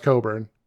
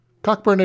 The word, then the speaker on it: Cockburn: that name, Cockburn, is pronounced Coburn.